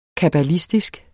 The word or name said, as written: kabbalistisk